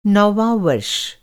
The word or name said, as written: नौवाँ